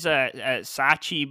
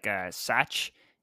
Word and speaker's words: Thatch: It says Sachi, but it's pronounced like Satch.